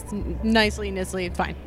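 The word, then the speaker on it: Knisley: So if there's a preferred pronunciation of your name, we can put it on there. – Sure, okay. Nisley is usually good, yeah.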